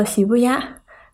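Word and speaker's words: Shibuya: 涩谷读作喜不压，和四二八是谐音。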